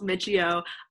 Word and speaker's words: Miccio: So my name is Brooke Miccio. I know no one ever knows how to pronounce the last name. It's three syllables, Miccio.